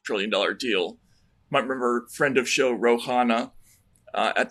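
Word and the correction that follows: Khanna: Did you say Rohana? – Is it Rokana? – Rokana, yeah. You sounded like Amy Goodman pronouncing.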